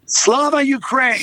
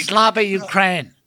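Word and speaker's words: Slava Ukraini: And how do you say it? Glory Ukraine? – Slava Ukraine. – Slava Ukraine. – There we go.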